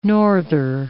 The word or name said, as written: norther